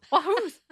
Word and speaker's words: Wahoos: How do you pronounce that? – Wahoos. – Like the fish, like a wahoo fish.